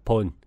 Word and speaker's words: Bon: Bon, từ Tây Tạng, phát âm là Pon.